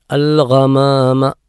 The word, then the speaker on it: الغمام: ഐനും ഗൈനും ഒരുപോലെയല്ല. ഇവിടെ ഗൈനാണ്, ഐനിന് പുള്ളിയുള്ള ഗൈൻ അൽ ഗമാമ ആ നിലക്ക്.